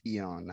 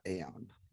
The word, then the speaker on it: Aeon: and it's pronounced Eon, not Aeon. But I learned that myself as well.